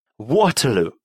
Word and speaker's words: Waterloo: Waterloo.